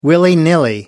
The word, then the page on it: willy-nilly: American English